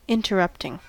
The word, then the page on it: interrupting: American English